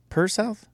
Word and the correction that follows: Pearsall: P-E-A-R-S-A-L-L. – Pearsall? – Pearsall.